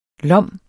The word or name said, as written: lom